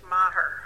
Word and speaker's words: Maher: The phonics, English phonics do not work on that name. It's pronounced Maher.